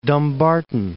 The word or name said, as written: Dumbarton